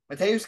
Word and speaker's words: Mateusz: How do you say it? – Mateusz Gamrot taking on Jalen Turner.